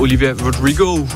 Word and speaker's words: Olivia Rodrigo: Stupid Song fik du her med Olivia Rodrigo.